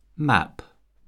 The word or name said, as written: map